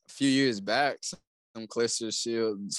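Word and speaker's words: Claressa Shields: Say her name correctly. – Okay. Let me repeat. Kalissa Shields, the GOAT, versus Savannah Marshall, the silent assassin for undisputed female middleweight.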